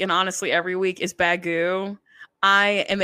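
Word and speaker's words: Baggu: So Bagu, it's spelled B-A-G.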